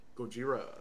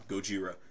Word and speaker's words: Gojira: Gojira. And it's not racist. That's the name. – Yeah. – Yeah. Some people... – I'm not being crazy. – No. There's that's literally how you pronounce it.